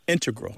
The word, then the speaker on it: integral: Just to clarify here, both correct, but the mathematicians mm-hmm. and math-using scientists are more likely to put, say, integral with the stress on the first syllable.